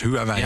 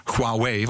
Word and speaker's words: Huawei: Wij als Huawei, ik zal het maar op zijn Nederlands uitspreken, wij als Huawei in Nederland zijn een Nederlands bedrijf. Mm-hmm. Huawei, want zo spreek ik het uit, want als ik het niet zo uitspreek, dan gaan mijn collega's in China naar mij kijken en zeggen, wat zeg je nou? – Oh, waar heb je het eigenlijk over?